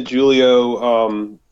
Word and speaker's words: Julio: Well, we were introduced to Julio. I'll use the hard J. That's how he says it.